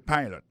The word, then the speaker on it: pilot: G-I-R-L-F-R-I-E-N-D, girlfriend. He is a pilot. Son pilote avion lié. He is a pilot. Pilot là, c'est même mot pilote là, il dit E-A-D-L-I, il écrit P-I-L-O-T. Alors, il s'est prononcé I dans le cas. Alors, et puis, dans le mot pilot là, tout, après accent tombé sur le premier syllabe là.